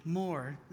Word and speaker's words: Moore: Over here, you would call somebody with the last name M-O-O-R-E, Moore, and we pronounce it Moore. Oh, I say, just remember a cow's job description, Moore.